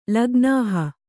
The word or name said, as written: ಲಗ್ನಾಹ